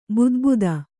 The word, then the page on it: ಬುದುಬುದ: budubuda